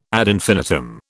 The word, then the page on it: ad infinitum: British English